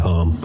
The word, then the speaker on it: Holm: My Website of the Week is something called Paper Home. – Right. – Which is spelt paper and then H-O-L-M. – Home.